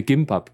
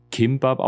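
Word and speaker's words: Kimbab (김밥): Es war an dem Tag, mittags, als wir Gimbab gegessen haben. Also eigentlich Kimbab ausgesprochen. Ich, ich sage immer bloß ein G am Anfang.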